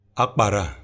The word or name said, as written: akpara